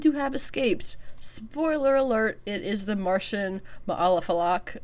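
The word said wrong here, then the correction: Ma'alefa'ak: What is his name? Ma'alafa'ak. Ma'alafa'ak. Yeah, okay. I said it wrong the first time.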